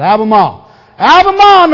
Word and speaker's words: Albemarle: Got to say it like the people from there say it. Leave the R out. Albemarle,